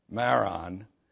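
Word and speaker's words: Merom: I know it says an M on the end, but all the Hebrew dudes say it's an N, pronounce an N. Maron.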